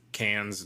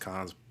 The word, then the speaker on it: Cannes: You might know it as Cannes. It's pronounced Cannes, but whatever.